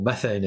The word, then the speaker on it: methane: But what wasn't really commented on at the time was the high fraction of that, that set of different molecules that was actually methane, or methane as it's sometimes pronounced.